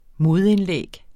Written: modindlæg

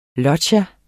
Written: LOGGIAERNES

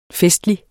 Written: festlig

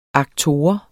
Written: aktor

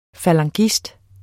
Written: falangist

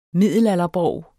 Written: middelalderborg

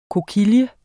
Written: kokille

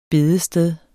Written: bedested

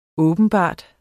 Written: åbenbart

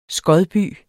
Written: skodby

